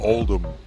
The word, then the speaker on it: Oldham: Oldham. – Without the H. Pronunciation.